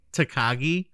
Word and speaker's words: Takagi: Takagi, there we go.